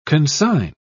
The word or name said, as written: consign